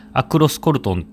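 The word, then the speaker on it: Aloxe-Corton: この中でいろいろちょっと発音の違いで、例えばアロースコルトンとかアクロスコルトンって読ませたりするところもあるんですけども、そこは相変わらず気にしなくていいです。アロースコルトンで、重要なのは赤だけを作っている、用紙を覚えてください。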